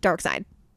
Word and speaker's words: Darkseid: No, it's spelled Darkseid. It's usually pronounced Darkseid. – Oh. – Anyway, that's my problem. IED or SEID.